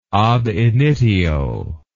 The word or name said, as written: ab initio